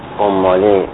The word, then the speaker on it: اماله: من اماله رو ظاهراً اماله تلفظ کردم، درست نیست، اماله و علامه.